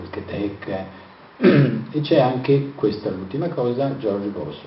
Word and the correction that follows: Jorge Boss: Jorge Boss, perché si pronuncia così,